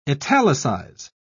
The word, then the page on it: italicise: British English